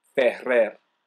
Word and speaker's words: Ferrer: Well, like, my last name is pronounced Ferrer, and I don't expect anyone to ever do that without, like, getting a tongue cramp or something.